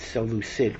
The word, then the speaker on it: Seleucid: Now, you pronounce it Seleucus, but when it's a dynasty, it's Seleucid for some reason. The C becomes a soft C.